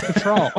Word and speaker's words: Cattrall: Is that how you say it? Cattrall? – Cattrall. – Kim Cattrall.